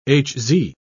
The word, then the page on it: hz: British English